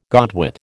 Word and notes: godwit: American English

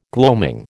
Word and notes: gloaming: American English

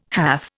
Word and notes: hath: Indian

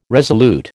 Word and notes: resolute: American English

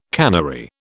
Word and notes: cannery: British English

